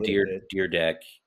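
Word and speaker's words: Dyrdek: Rob Deerdeck. Is that how you say it? – Yeah.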